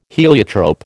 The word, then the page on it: heliotrope: American English